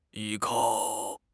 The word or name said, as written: 依靠